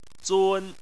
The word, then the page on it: zun: 1